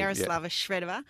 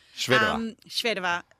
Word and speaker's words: Yaroslava Shvedova: Ash Barty seems an obvious one, but how do you team up with someone like Yaroslava Shvedova? And I hope I've pronounced that. – You did very well, actually. – Thank you. – Yaroslava Shvedova. – Shvedova.